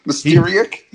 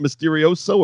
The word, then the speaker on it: Mysterio: And it's amazing what time will do, because JR can finally pronounce Mysterio. That's a throwback to our last episode where he couldn't do it. – Mysterio? – Hey, he nailed it every time on this show. It wasn't Mysterioso or Mysterion or Mysteriac.